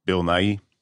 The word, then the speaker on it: Nighy: I have never known how to pronounce this guy's name. – Bill Nighy. – Thank you.